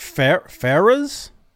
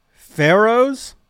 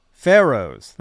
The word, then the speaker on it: Pharaoh's: Is it Fer Ferras? Fer. I have to in the chat. Can somebody pronounce that for me? I don't have to ask Siri. Pharaohs, Pharaohs. Thank you, Halface. Oh, like Egyptian pharaoh.